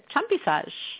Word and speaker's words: Champissage: Tell me if I'm pronouncing this right. Champissage. – Pretty good. Champissage.